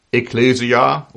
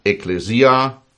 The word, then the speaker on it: Ekklesia: Nun, das Wort für Gemeinde oder Kirche im Griechischen ist das Wort Ekklesia oder Ekklesia, je nachdem, wie man es aussprechen will, E-K-K-L-E-S-I-A. Ekklesia, Ekklesia.